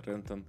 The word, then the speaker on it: Рентон: Э, на самом деле ударение на слово на, на слог Е Рентон.